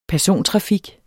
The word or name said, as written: persontrafik